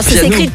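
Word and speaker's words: Pjanoo: Si je te dis Piano, alors Piano. Ça s'écrit Bjano. Hein. – Ah, c'est Bjano, donc c'est du suédois ça.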